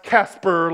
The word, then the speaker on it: Caspar: One tradition even gave them names, like Balthazar and Melchior and Caspar, or pronounced Casper like the friendly ghost.